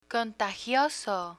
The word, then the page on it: contagioso: Spanish